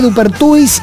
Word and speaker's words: Dupertuis: Dupertuis se escribe igual como se pronuncia.